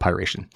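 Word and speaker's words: Py#ration: According to the, the threat analytics company Secure, Securonix, this malware is a remote access Trojan, or a RAT, dubbed Pyration, and that's spelled P Y pound sign R A T I O N. So I'm, I'm going to call it Pyration.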